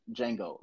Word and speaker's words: Django: Oh, yeah, Django. Like, that's the only thing I kept thinking about. Not Django. – Because like, the D is silent.